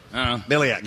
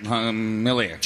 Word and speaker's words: Mihalich: Phil Martelli as well, Ashley Howard, Zach Spiker, and Joe, how do I pronounce his last name? Miliak. – Miliak. – Joe versus the volcano. – He's got an interesting, M-I-H-A-L-I-C-H.